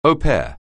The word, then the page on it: au pair: anglais